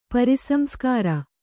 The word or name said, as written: ಪರಿಸಂಸ್ಕಾರ